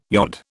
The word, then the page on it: yod: British English